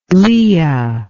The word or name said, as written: Leah